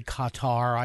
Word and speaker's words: Qatar: You point out, and one of the bullet points I have here, Pastor Biltz, the role of I still say Qatar. I know they pronounce it eight different ways now, from when I would cutter and gutter and Qatar. But but to make it easier, Qatar was how I was raised in journalism school on it.